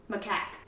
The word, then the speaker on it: macaque: Macaw's a bird. Macaque. – But it's pronounced macaw. – No, it's not. Macaw's the bird, the parrot. – I believe they're both pronounced macaw.